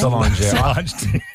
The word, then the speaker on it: Solange: Is that how you say her name? – Solange.